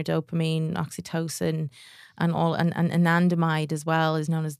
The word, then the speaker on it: anandamide: So there, how do you pronounce it? – Anandamide. So I think ananda isn't it Sanskrit? It means bliss. So anandamide is the bliss chemical.